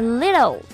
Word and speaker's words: little: T T 字母呢，这个浊化成了的的发音。类似的还有这个 better，letter 等等。他在唱的时候，这首歌的节奏是 just a little change。这个 just a little 进行了重读 just a little change 是这样一个节奏。